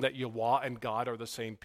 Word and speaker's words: Yahweh: Some people pronounce it Yahweh. It's really pronounced Yahweh. Some people totally botch it up based on a Middle Age misinterpretation and pronounce it Jehovah.